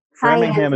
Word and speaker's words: Framingham: Framingham is totally the correct way to pronounce that.